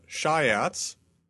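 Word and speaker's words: Siats: It's called Shiats. I don't know why. I forget the etymology of the name, but it's called Shiats, and it's not a tyrannosaur.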